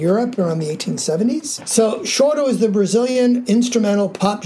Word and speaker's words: choro: It's called Choro. – Yes. Can you tell everyone that it's pronounced that way and it's spelled C-H-O-R-O. So it's not correct. Choro. It's choro.